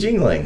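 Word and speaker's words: Yuengling: Are you even sure you're Yingling. Pronouncing it correctly? – What, Yingling? – Yeah. – Of course, it's Yingling.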